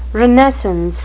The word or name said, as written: renascence